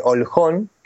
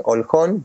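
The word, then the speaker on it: Olkhon: y llegamos hasta la isla de Holjón, que se escribe Olhon, Holjón, por si alguno también la quiere buscar por ahí en Google, donde están, bueno.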